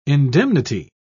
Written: indemnity

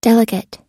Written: delegate

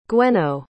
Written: gwenno

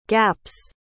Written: gap's

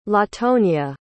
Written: latonya

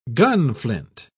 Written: gunflint

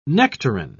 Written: nectarine

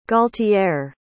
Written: gualtier

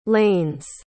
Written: lanes

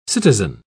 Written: citizen

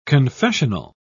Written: confessional

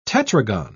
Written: tetragon

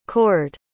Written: cored

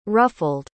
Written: ruffled

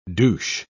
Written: douche

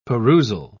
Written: perusal

